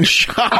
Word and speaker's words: Schock: You know, I was going to give him a pass, but I noticed that you sent me some material on Representative Aaron Schlock. I mean, sorry, Aaron Schock.